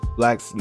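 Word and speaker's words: 6lack: Well, Black is a for those that don't know, Black is an R&B artist and it's pronounced it's spelled with a six and L A C K, so it's like it's spelled like six lack, but it's pronounced black.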